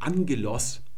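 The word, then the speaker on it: Angelos (ἄγγελος): Und das spricht man also nicht Ptogos aus, sondern das hier, Doppelgamma, ist immer die Schreibung für NG. Auch in Angelos, der Engel, wird Doppel-G geschrieben.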